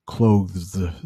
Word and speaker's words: clothes: Clothes. There's a TH there, and then there is an S. Clothes. Yep. So you know what? A lot of times, I think we just pronounce it like clothes. Hey, where should I put my clothes? We don't even think about that TH there. We pronounce it like, hey, can you close the door? If you would, close the door, close.